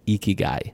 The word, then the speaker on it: Ikigai: I believe it's Ikigai. – Ikigai. – I-K-I-G-A-I.